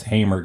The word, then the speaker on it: Hamer: I always pronounced it Hammer, but it's Hamer Guitars.